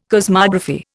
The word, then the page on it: cosmography: Indian